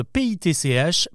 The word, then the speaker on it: pitch: Pour un anglo-saxon, ces deux mots sont impossibles à confondre car ils ne se prononcent pas du tout pareil. Le premier s'écrit SP2ECH, speech, et l'autre PITCH, pitch. Sauf que nous, les Français, nous prononçons les deux avec le son I, un speech et un pitch.